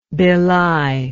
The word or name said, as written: Bhilai